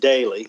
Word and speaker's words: Doelle: It, it's spelled D O E L L E, but it's pronounced daily.